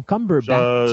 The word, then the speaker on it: Cumberbatch: D'abord, Benedict euh, Cumberbatch, Cumberbatch, tu Comment tu prononce son Moses de nom? – Cumberbatch.